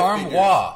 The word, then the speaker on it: armoire: Okay, so a Reddit user on Thursday posted a screen grab of Wayfair's website showing armors that cost five armoire. – Figures. Oh, really? – It's a French word. We are crushing the French pronunciation.